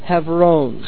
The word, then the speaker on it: Hebron: Somebody asked, what's the correct pronunciation of Hebron? It would be, in Hebrew, Hebron.